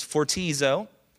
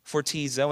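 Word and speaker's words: fortizo (φορτίζω): Here's how you pronounce it first. It's fortizo, fortizo, and it means a person weighed down with heavy loads.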